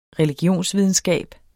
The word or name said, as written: religionsvidenskab